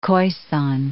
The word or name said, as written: Khoisan